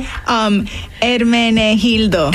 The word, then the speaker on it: Hermenegildo: Hermenegildo? – Hermenegildo. – Hermenegildo. Okay, see, as you can tell, folks, I did not take many Spanish classes. – No, yeah, it's the H is silent.